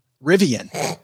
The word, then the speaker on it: Rivian: Let's talk about Riven. Like, there's one, Rivian.